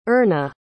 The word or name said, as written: earna